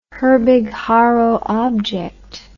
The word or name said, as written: Herbig-Haro object